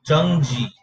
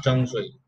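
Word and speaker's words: 橙汁: So Chung Shui is orange juice. Chung Ji, not Chung Shui. Shui is water. Chung Ji, Chung Shi is orange juice. Chung Ji.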